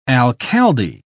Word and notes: alcalde: British English

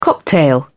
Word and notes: cocktail: British English

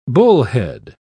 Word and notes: bullhead: British English

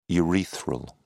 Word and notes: urethral: British English

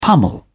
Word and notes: pummel: British English

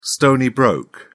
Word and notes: stony-broke: British English

